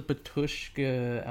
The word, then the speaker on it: Batushka: So exactly, because like the Batushka album that we were referring to earlier, and that is the correct pronunciation, no matter what anyone says,